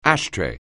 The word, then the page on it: ashtray: anglais